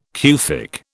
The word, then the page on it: Kufic: British English